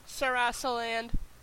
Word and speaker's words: Sarasaland: Sarasaland. – Oh, okay, Sarasaland, okay.